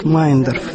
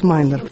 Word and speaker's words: Мейендорф: Это мемуары Марии Федоровны Мейендорф. Фамилию они именно так произносят, Мейендорф. Все пытаются произнести так, как пишется, но на самом деле семья вся сама произносит Мейендорф.